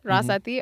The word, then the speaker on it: Rasathy: R-O-L-E-X, Rolex, Rasathy, mm-hmm. R-A-S-A-T-H-Y, Rasathy.